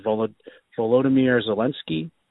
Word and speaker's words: Volodymyr Zelensky: but I believe it's pronounced Volodymyr Zelensky.